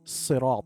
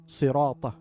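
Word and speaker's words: sirat (صراط): Correction: ensure correct pronunciation of sirat, sirat, as sad should be pronounced with a heavy sound.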